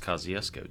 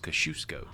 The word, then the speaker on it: Kosciuszko: You don't say, it's actually not pronounced Kosciuszko. Do you know that? – Oh, well, what is it usually? – It's supposed to be pronounced Kosciuszko.